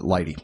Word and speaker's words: lighttpd: And then there's another one that runs on Linux called Lighty, and it's actually L-I-G-H-T-T-P-D. It's like light plus HTTPD is Lighty. It's, that's how you pronounce it, Lighty.